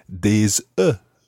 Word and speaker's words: œufs: But if you're looking for more than one egg, it's des œufs. – Des œufs. – And it's spelled O-E-U-F-S, but it just sounds like œuf.